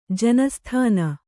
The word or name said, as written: ಜನಸ್ಥಾನ